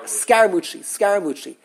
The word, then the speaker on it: Scaramucci: Scaramucci.